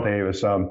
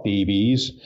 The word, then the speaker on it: Davies: First, I want to compliment you because when you mentioned Ray Davis and Cyril Davis, their last names were the Welsh spelling D-A-V-I-E-S that most Americans call DBs. but the pronunciation is, as you correctly mentioned, Davis.